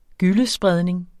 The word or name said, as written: gyllespredning